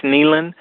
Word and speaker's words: Nehlen: It's pronounced Nehlen, but it's spelled N-E-H-L-E-N for Congress.